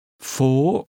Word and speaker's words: Phố: Phố. – This is pronounced with a high, rising tone.